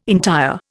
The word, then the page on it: entire: Indian